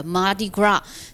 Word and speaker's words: Mardi Gras: 人也包括我哈，曾经把这个音发错了，会发成 Mardi Gras 哈，见字发声啊，很多人会容易把这个单词尾巴上的 s 发出音来。其实呢，大家不用感到很难堪哈，因为这本身其实它的来源就不是英文，而是一句法语。 – 对，是个法语单词哈。所以在读的时候，这个末尾的 s 它是不发音，叫 Mardi Gras。